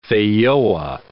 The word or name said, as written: feijoa